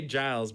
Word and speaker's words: Giles: Is it Giles? Okay, yeah. I wanted to say Giles, but I didn't know if that was the hard G.